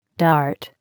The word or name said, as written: dart